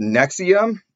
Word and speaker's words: NXIVM: And so a patient recommended one about a cult called Nexium. It's spelled N-X-I-V-M.